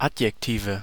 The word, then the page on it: Adjektive: /ˈad.jek.tiː.ve/